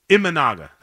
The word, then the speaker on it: Imanaga: They have got to figure out a way to score some runs, and I know Amanaga, I always say that word wrong, who's now, I always say his name wrong. Say it to Imanaga, thank you.